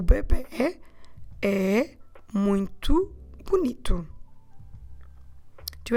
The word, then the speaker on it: bebê: We say o bebê. O bebê. Bebê, B-E-B-E com acento. So it's B-E-B-E with an accent on the last E to the right acute accent.